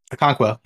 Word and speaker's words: Okonkwo: Chigozim Okonkwo. – Mm-hmm, mm-hmm. – Chigozim Okonkwo. – Okonkwo, yeah. – Nailed it.